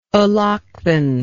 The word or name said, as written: allochthon